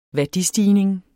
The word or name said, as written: værdistigning